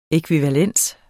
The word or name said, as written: ækvivalens